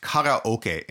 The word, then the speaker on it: karaoke: Well, so karaoke, it's actually pronounced karaoke in Japanese. Kara meaning empty and oke meaning orchestra.